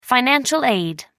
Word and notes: financial aid: American English

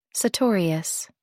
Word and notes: sartorius: American English

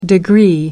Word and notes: degree: American English

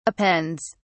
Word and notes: appends: British English

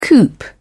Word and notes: coop: British English

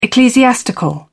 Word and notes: ecclesiastical: British English

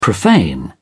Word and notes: profane: British English

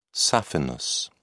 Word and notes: saphenous: British English

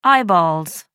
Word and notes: eyeballs: American English

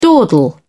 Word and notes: dawdle: British English

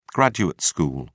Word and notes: graduate school: British English

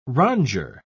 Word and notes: rondure: British English

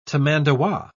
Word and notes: tamandua: British English

